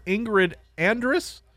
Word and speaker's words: Ingrid Andress: This one involving country singer Ingrid Andress.